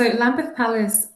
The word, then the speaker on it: Lambeth Palace: So Lambeth Palace, you pronounced it perfectly, actually.